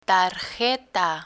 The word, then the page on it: tarjeta: Spanish